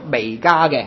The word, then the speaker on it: 弥迦: 好，我哋继续睇弥迦书嘅第三章至第五章吓。咁见到咁多弟兄姊妹未攞笔记，啱啱先攞咧，咁我再提一次吓，小事嘅啫。咁我讲开呢一卷书咧，就提过嗰、那个应该个发音咧，应该读弥迦嘅吓。